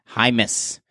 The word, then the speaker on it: Jaimes: And a fire round contributant from a couple weeks back, Zach, wants to send an E-E-E and let us know that his last name is pronounced Jaimes, not James. It's J-A-I-M-E-S, I believe. So I guess it's maybe, what is that, Spanish maybe? – Jaimes. – Not James. Jaimes.